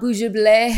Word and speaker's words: Bugible: Yeah, so I am at Bugible, B-U-G-I-B-L-E. – That's how you pronounce it. I wasn't sure how to pronounce it. – Not Bugible. Okay. It's like bug and edible. So Bugible.